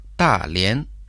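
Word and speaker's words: Далянь: Та – это означает «большой», произносится в четвертом тоне, а Лен – «соединяться», произносится во втором тоне. Повторите за мной. Далиан.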